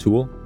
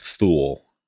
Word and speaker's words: Thule: I don't think you said that right. – Thule? – It's Thule.